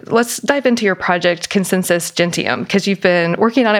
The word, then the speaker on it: Consensus Gentium: So Consensus Gentium is Latin for if everyone believes it, it must be true.